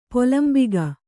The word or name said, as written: ಪೊಲಂಬಿಗ